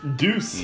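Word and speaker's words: Deus: D E Deuce.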